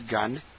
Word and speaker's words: Gunn: The last name is Gunn, G-U-N-N.